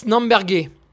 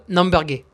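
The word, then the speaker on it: Namberger: Troisième favori, Hannes Namberge. Alors là, je l'ai bien prononcé. Voilà, pour tous ceux qui prononcent mal, Namberge. Voilà, vous dites Namberge.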